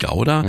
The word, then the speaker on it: Gouda: Richtig gehört, Gouda, mhm, denn Curaçao gehört zum Königreich der Niederlande.